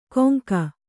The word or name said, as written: ಕೊಂಗ